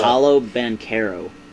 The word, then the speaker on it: Paolo Banchero: I think Chet goes number two, and then Pancho Bachero, is that how you say his name? – Pancho. – How do you say his name? I have no idea. – Paolo Banchero.